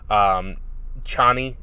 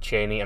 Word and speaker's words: Chani: Chani is how they pronounce it in the movie. The book pronounced it Cheney.